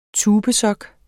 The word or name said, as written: tubesok